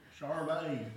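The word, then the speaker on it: Sade: I think you pronounce it Sadie. Is that? Charday. Have you heard of her, Sadie? – Charday. It's pronounced Charday.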